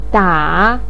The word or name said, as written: da3